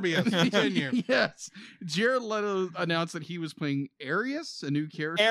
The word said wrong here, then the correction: Ares: Ares. – I'm sorry. Ares.